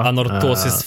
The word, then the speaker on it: Anorthosis: Eh, grattis till Lilmix och Anorphosis. Svårt namn att uttala. – Anorthosis eh, famogusta.